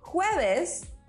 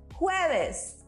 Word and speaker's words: jueves: So it's miércoles, jueves. Did you notice we pronounce the J as if we were saying who, but a little bit stronger?